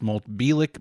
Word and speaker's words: Bielek: I've heard that pronounced Bielek. – Bielek. – Yep.